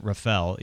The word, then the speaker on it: Rafael: I think San Rafael is just, first of all, they pronounce it Rafael, even though it's Raphael.